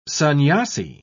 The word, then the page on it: sannyasi: British English